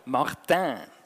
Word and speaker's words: Martin: Einfach französisch ausgesprochen, Martin.